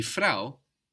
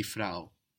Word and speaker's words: Frau: Die Frau, the woman, is spelled F-R-A-U. It ends in a vowel, but it doesn't really end in a vowel sound. Die Frau. It ends in a W.